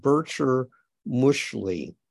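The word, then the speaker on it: Bircher muesli: Yeah, I think you've given us the correct pronunciation of that word you used at the beginning of this lesson, and I think it's now Bircher Mushli.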